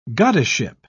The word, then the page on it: goddessship: British English